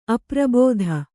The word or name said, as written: ಅಪರಾಬ್ಧಿ